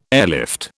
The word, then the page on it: airlift: British English